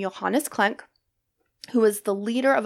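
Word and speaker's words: Klencke: It's K-L-E-N-C-K-E. – Okay. – So I would say Klenk.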